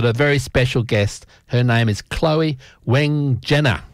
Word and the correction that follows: Wegener: and I hope I pronounced that right, Chloe. – Close enough, Wegener. – Yeah, Wegener.